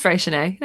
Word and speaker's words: Freycinet: What is it? Freycinet. – Help us out. – Just Freycinet. No, she said it right.